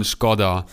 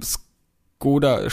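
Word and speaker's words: Škoda: Unter dem Emblem haben wir dann ähm, den Skoda-Schriftzug und dazu muss man jetzt sagen, wir äh, deutsche Kartoffeln haben es auch von Anfang an falsch ausgesprochen. Skoda heißt nicht Skoda, sondern Skoda. – Und mm. ähm, beim Skoda Skoda,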